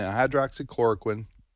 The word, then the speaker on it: hydroxychloroquine: And I, as I've said before, you really think that these things that people use, like ivermectin and hydroxychloroquine, did I pronounce that right? – Yes.